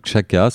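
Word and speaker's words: Xaka: Euh, moi, je vous recommande le Chaka. Ça s'écrit X-A-K-A à Trouville.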